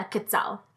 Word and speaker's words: quetzal: and I feel like I'm going to say this wrong, is called quetzal. – Yeah, quetzal.